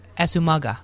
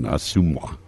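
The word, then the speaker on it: Asomugha: The pronunciation that it makes more sense than the pronunciation I was given, Asumwa, because there is a G in it.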